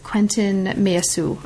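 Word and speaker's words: Quentin Meillassoux: and that is Quentin Meyesu. And forgive my pronunciation of everything. Is that that's right? That's correct.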